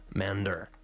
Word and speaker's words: MNDR: And we just heard there was MNDR, which is pronounced Mander, which we heard I Go Away off of their EP called EPE through the Wonder Sound label.